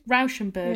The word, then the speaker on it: Rauschenberg: a friend of mine had um, a Robert Rush- Rauschenberg. Rauschenberg? How do you say it? Yeah, Rauschenberg, I think. Rauschenberg. – Yeah.